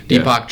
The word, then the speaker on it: Deepak Chopra: How do you say his name? – Deepak Chopra.